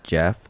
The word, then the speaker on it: Geoff: I'm pretty sure it's like Jeff. Like Jeffrey, but it was G-E-O-F-F.